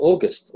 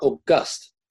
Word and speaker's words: August: They announced at one board meeting, they said, we're going to be called August. I said, okay. I said, you sure it's not August? being a classicist. And they said, what? I said, well, you can pronounce it either way.